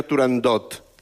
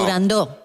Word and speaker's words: Turandot: No se dice, no se debe pronunciar Turandot. – No, Turandó. – La ópera se titula Turandó.